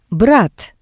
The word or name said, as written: brat